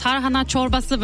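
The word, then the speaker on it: Tarhana Çorbası: Tarhana Chorbasse wird das ausgesprochen. Qualitativ hochwertiger Joghurt mit Weizen und es dauert halt wirklich mehrere Tage, bis das trocknen kann.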